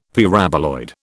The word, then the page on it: Paraboloid: British English